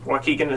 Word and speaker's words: Waukegan: Waukegan is right.